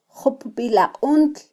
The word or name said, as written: jupbilaquntl